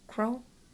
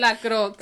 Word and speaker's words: La Croix: L-A-C-R-O-I-X. La Croix. Qua. Crow? Quix. – No. Croix. – Croix. – La Croix. – Let's just say it. – La Croix.